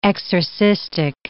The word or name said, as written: exorcistic